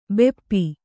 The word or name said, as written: ಭೂಪ